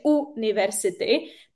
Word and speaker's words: university: A naopak u slovíčka university, i přestože se to píše university, tak první se čte j, university, tak první, čte, eh, tak proto používám e, takže a e, university.